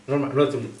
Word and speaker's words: Normani: Normani, sì, ok, detto bene, ok.